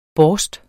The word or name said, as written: borst